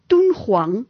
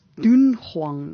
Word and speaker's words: Dunhuang: So 'n pleknaam wat gespel word D-U-N-H-U-A-N-G moet uitgespreek word as Toonghuang en nie as Doonghuang nie.